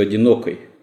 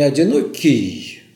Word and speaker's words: одинокой: Для большинства наших современников еще рифма будет до конца неуловима, потому что у Лермонтова в оригинале еще «одинокой». – Да. – Тогда было произношение «одинокой». – Совершенно верно. Но это только деталь в этом случае. Отец Андрей говорит о том, что у Лермонтова не «одинокий», а «одинокой». «Белеет парус одинокой».